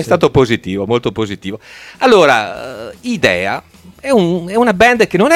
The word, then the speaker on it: Hidea: Idea. – Semplicemente così. – Idea all'italiano. Bello, limpido, idea all'italiano.